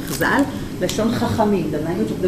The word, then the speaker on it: Chazal: And you pronounce it shortly as Chazal.